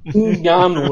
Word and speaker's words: Ngannou: Kannst du den Nachnamen bitte nochmal aussprechen? Ngannou, oder wie der heißt, dieser Franzose. – Sehr gut.